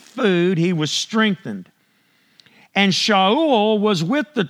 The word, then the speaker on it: Shaul: So Ananias went away and went into the house, and laying his hands on him, he said, Brother Shaul, which is what it, how it would really be pronounced,